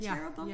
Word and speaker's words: cherubim: Cherubim? – Yeah, cherubim.